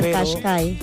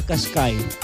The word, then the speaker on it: Qashqai: la tarea de investigar la pronunciación, ya que es un poco, digámoslo así, enredado, por decirlo. – Sí, Nisa Qashqai. – Ajá, Qashqai. Así como lo escucha, me opto por ese modelo en estos momentos.